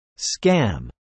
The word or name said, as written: scam